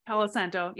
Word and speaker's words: palo santo: Those people, I will light either sage or, I always say this wrong, I think Palo Santo wood. – Palo Santo.